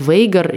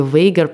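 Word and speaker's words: Вхагар: По-русски она почему-то пишется как в Хагар, что совершенно непроизносимо. Они все-таки ее называют Вейгар, и я бы предпочла назвать ее Вейгар, потому что это я хотя бы могу произнести.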